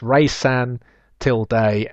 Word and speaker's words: Resan Till Dig: It is Resan Till Dig, pronounced Resan Till Day, and means Journey to You by Eurovision champion Alexander Rybak, pronounced Rybak.